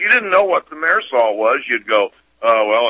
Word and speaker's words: thimerosal: And I believe I'm pronouncing that correct. – Right. – Is that right, Doctor? – Yeah. – Right. – Mm-hmm. – Right. – And the thimerosal is the mercury derivative preservative.